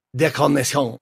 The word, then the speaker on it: Decarnation: It's decarnation. – Okay. – But it's probably pronounced décarnation because it is. – is it translated from French.